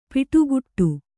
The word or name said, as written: ಪಡಿಕಟ್ಟು